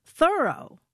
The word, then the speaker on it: Thoreau: All this time, I thought his name was pronounced Thoreau, but no. – How is it pronounced then? – Thoreau. – Like I thoroughly did it. – Yeah, or I'm thoroughly surprised that it's pronounced that way.